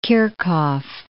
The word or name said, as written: Kirchhoff